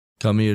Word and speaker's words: Cumie: No, I'm calling her Kumi. – Yeah, it's spelled C-U-M-I-E, so of course, I actually did not give her the benefit of the doubt at all while reading the story. – Kumi it is.